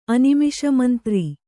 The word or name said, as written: ಅನಿಮಿಷಮಂತ್ರಿ